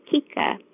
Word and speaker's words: Keika: My first name is spelled K-E-I-K-A, and people often pronounce it as Keika. The correct pronunciation is cake, as in bake a cake, then ah, as if you're tasting a cake.